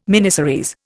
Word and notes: miniseries: Indian

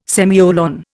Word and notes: semicolon: Indian

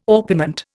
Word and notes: orpiment: Indian

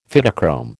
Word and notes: Phytochrome: American English

